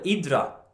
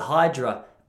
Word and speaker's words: Hydra: They called it uh, if you're Greek, you call it Idra, is how they say it. I call it Hydra.